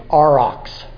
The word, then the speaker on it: aurochs: The aurox is what it was. It's spelled A-U-R-O-C-H-S. Aurox. Check that out in your dictionary when you get a chance.